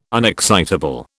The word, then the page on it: unexcitable: British English